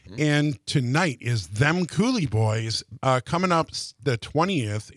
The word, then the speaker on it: Coulee: That's spelled C-O-U-L-E-E for Cooley.